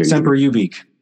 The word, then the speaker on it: Semper Ubique: Semper Ubique.